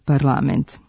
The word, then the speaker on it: parlament: Pravilan naglasak je parlament, jer akcenti u srpskom jeziku ne mogu da stoje na poslednjem slogu u reči.